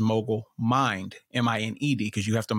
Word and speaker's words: Mined: And so Mogul Mind came about. And the reason why it's spelled M-I-N-E-D is because when you hear it, you still hear mind.